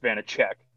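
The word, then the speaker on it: Vaněček: Well, I will first start by correcting you, because according to Hockey Reference, it is pronounced Vanacek.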